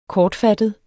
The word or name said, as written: kortfattet